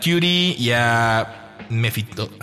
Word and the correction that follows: Mefistófeles: Mefistófeles. – Mefistófeles.